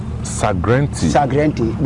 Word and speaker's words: Sagrenti: Sagrenti. – Because Ashantis couldn't pronounce Ghanet Wolseley. – Jesus. – Because of that, they pronounce it Sagrenti.